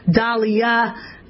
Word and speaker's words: Daliah: Dahlia, D-A-L-I-A-H. You say your name so damn much. It slurs. Dahlia.